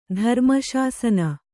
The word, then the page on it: ಧರ್ಮಶಾಸನ: dharma śasana